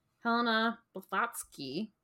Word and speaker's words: Blavatsky: But yeah, today we're going to be talking about Helena Blavatsky.